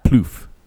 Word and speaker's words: pluff: So we've got yap and pluff. – Say it one more Hold time. On. – Yap and ploof or pluff? – Ploof. – How would you say it's spelled P-L-U-F-F. – Pluff.